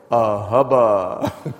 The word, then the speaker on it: ahaba: It's spelled A-H-A-B-A. And you say it this way, ahubba.